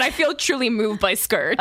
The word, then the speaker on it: Skurt: S-K-U-R-T. It's Skirt.